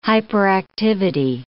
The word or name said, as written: hyperactivity